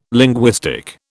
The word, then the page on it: linguistic: British English